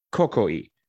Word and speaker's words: Coecoei: But they have a unique liqueur, and it's called Cocoi. It's spelled C-O-E-C-O-E-I. Cocoi, which is a crimson liqueur.